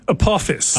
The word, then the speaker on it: Apophis: We're going to see it in April of 2029, and that's Apophis. Am I saying that right? – Apophis.